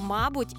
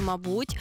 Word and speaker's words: мабуть: Також слово «мабуть» і «мабуть» теж має два законних наголоси.